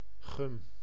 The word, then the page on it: gomme: /ɡɔm/